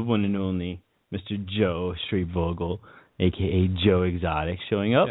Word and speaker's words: Schreibvogel: Yes, I like how you pronounce his last name. Very good. – I think it's it. Schreibvogel or Schreibvogel. – Tomato, tomato, but I'm glad you didn't stumble all over it.